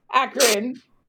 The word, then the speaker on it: Akron: Akron.